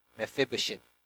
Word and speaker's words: Mephibosheth: You pronounce the word swish, swish, swish, Mephibosheth, Mephibosheth, Mephibosheth.